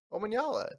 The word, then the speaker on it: Omanyala: So Omanyala, it's easy.